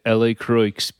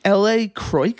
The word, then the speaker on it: LaCroix: You say L.A. Croix? – L.A. Croix. – That can't be, though. – That's how it's pronounced. – Is it really? It's not LaCroix? – It's L.A. Croix. – Okay. I can't tell. You're confusing me. – It is L.A. Croix.